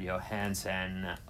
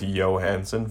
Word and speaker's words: Johansson: I'm pretty much fucking with you, but I think we do a hard J on that. – Okay, so Scarlett Johansson.